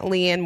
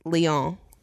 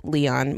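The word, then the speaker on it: Liane: Apparently, it's not Leanne Moriarty. It's Leon. – What? – As in Celine Dion. It rhymes.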